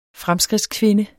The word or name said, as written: fremskridtskvinde